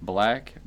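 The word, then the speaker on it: 6LACK: The artist, who his name is supposed to be Black, but it's spelled 6LAK.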